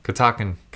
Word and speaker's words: Catoctin: Catoctin. – that's good. – Did I say it right? – Catoctin. – Catoctin. Yeah.